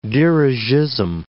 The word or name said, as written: dirigisme